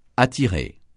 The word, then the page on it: attirer: francais